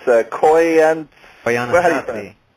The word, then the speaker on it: Koyaanisqatsi: It's Koyaanisqatsi.